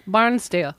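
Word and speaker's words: Barnsdall: ¿Así se pronuncia? – Barnsdale, yo creo. – Barnsdale, ¿verdad? Ok, Barnsdale, porque lo han escrito Barnsdale.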